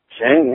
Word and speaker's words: Singh: help me pronounce this, Singh? – Singh, yes, yes, Ranjan Singh's little adopted nephew.